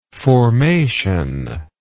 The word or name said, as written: formational